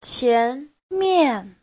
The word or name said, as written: qian2mian4